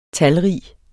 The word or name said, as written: talrig